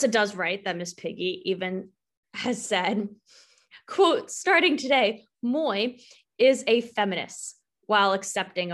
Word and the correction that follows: moi: I think she says moi. – Moi. Is that how you say it? M-O-I. – Because it's French.